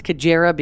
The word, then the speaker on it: Cajairah: Sadly, right down to the pronunciation of her name, although we know it's Cajairah because that's how her family says it in an interview, discrepancies and misinformation have plagued this strange case.